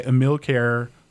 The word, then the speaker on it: Ponchielli: But this is Dance of the Hours, and this is by Emile Kerr. That is literally how you pronounce it.